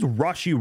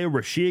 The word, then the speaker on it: Rashee: So it is Rashee Rice. Uh, I might say Rashi again. Uh, so if I do, I apologize, but I'll try to catch myself and uh, and correct that. But it is Rashee Rice. That is how you say it, like R A W, Rashee Rice.